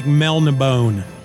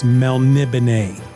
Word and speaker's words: Melniboné: It looks like Melnibone, but it's pronounced Melnibone.